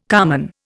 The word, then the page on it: carman: Indian